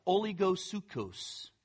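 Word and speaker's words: oligosukos (ὀλιγόψυχος): It's a oligosukos. Oligosukos. It's a compound word where oligo means small or little, and sukos means soul.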